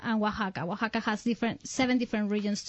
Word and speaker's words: Oaxaca: It's called Oaxaca. – Okay, but it's spelled W-A-H-A-K-A.